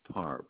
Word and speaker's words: Parr: Ronald Parr. P as in Peter, A-R-R.